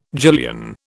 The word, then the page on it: gillion: British English